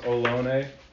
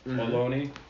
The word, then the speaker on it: Ohlone: So the Ohlone people, and I'm not exactly sure about the pronunciation there, but I believe it's, Google told me it was Ohlone, Ohlone, and so that's what I'm going with.